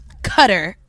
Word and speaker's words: Qatar: Qatar is the way to pronounce it.